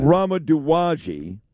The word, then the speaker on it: Rama Duwaji: How do you say her name again, Noam? Is it Rama Duwaji? – It is Rama, that's correct. – Rama Duwaji.